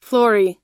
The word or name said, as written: flory